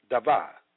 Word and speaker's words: dabar: The Hebrew word for word is dabar, pronounced dava, meaning speech, word, speaking, utterance.